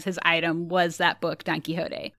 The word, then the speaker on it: Don Quixote: He signs up voluntarily for a 6-month stay to, quit smoking and to read a book, Don Quixote.